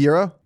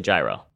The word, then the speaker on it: gyro: I guess I'd probably say gyro. – Okay. – Yeah. I'm not a silent G type of guy.